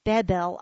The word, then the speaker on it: Babel: That passage states, "And the beginning of his kingdom was Babel," which is properly pronounced Bebel, I learned, "and Erech and Akkad and Calneh in the land of Shinar.